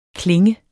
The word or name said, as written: KLINGEN